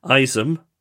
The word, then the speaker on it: Isom: Also, if you could give a shout out to my amazing husband, Isom, rhymes with rice gum, Isom.